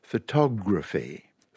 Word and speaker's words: photography: Stress on the second one, photography. Photography, not photography, but photography. Stress on the third syllable, photographic, all right?